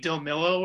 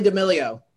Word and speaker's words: D'Amelio: DeMillo, or however you pronounce her name, the TikTok star. So Charli D'Amelio.